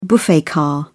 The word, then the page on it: buffet car: British English